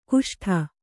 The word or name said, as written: ಕುಷ್ಟೆ